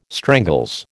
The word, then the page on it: strangles: American English